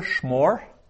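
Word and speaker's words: shmor (שְׁמֹר): Second masculine singular, shmor. Shmor, so a very short first syllable with a shva, shmor.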